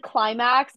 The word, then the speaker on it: Qlimax: There's like I think it said Climax, but it's spelled Q-L-I-M-A-X.